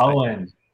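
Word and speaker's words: Eoin: Owen, it's Owen.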